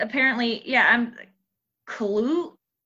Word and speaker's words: Cthulhu: So, the last one on here, it says Cthulhu is pronounced Kalulu because we've all wondered it. It's KHLUL-LOO.